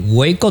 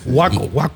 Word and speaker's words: Waco: Hay una jueza de Waco, Texas. Yo cuando iba para Wichita, pasé por ahí por Waco, Texas. Sí, Waco. – Waco es en inglés, en español es Waco.